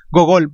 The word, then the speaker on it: Gogol: Nicolás Gogoy, Gogol, perdón, eh,